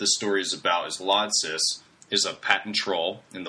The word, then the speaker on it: Lodsys: Apple to Lodsys, is that how you guys pronounce that? – That's how I would say it.